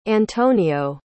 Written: antonio

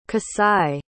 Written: kasai

